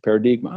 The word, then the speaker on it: paradigma: And paradigma is paradigm with an A on the end, and that's phonetically pronounced. It's a Portuguese word for paradigm, paradigma.